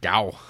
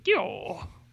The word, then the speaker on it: Gyo: Like, they've never expressed interest in manga, but maybe I'll give them Gyo. See what they think of that. – Or is it Gao? – I like to pronounce it Gyo.